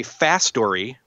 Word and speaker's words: Fastory: So this is made by Fastory, which is spelled F-A-S-T, like fast, and then O-R-Y. So it's kind of putting the words fast and story together.